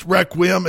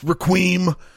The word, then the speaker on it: Requiem: Yes, it's pronounced Requiem. It's not pronounced Requiem. So fuck off about that.